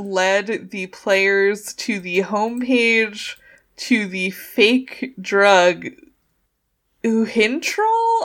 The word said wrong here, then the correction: Uthintrol: I want to say is how you pronounce it. Oh, it's Uthintrol, sorry.